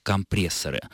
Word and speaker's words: компрессоры: Исторически правильным, традиционным будет все-таки окончание «ы». Поэтому правильно это слово произносить как «компрессоры». Форма на «а» является либо профессиональной, может быть, кстати, вот это сподвигло владельцев магазина дать столь корявое название, либо это просторечие.